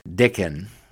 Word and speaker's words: Dickon: I checked that too. That is the pronunciation. Dickon.